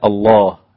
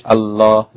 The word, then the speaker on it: الله: كذلك اسم الجلالة الله، اللام فيه مغلظة، ما تقولش الله، لا الله هكذا.